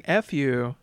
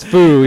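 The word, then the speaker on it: Fu: I went over to Elson Page's house and I played Boomerang F you. You gotta make sure you tell them how to say it. – It's foo. Yeah, I know.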